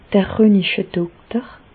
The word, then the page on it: d'r Rönichedokter: Bas Rhin; Reichshoffen